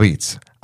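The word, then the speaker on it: Apizza: So that's a Neapolitan pronunciation of pizza, right? Mm-hmm. Abizza. Abizza. We usually take the last vowel and we put it before the word.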